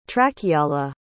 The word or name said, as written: trakiala